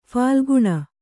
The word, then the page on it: ಫಾಲ್ಗುನ: phālguna; Kannada